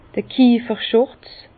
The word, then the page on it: d'r Kieferschùrz: Bas-Rhin; Herrlisheim